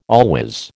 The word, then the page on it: always: American English